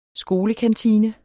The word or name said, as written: skolekantine